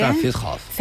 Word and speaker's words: Zentralfriedhof: Zentralfriedhof.